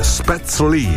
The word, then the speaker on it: Spätzle: Spätzle si pronuncia Spätzle.